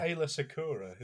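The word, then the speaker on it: Aayla Secura: How do you pronounce it then? – Called Alia Secura. It's Ayla Secura, isn't it? – That one.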